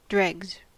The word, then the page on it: dregs: American English